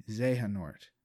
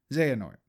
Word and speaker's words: Xehanort: It's spelled X-E-H-A-N-O-R-T. Zehanort. Zehanort.